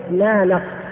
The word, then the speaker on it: نانق: هذا الرجل اسمه نانق أو نانق نانق نانق نون ألف نون قاف، أحيانا يحط بدل القاف كاف عليها إشارة كذا، يعني نانق نانق بالنطق الهندي نانق.